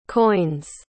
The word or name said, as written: coins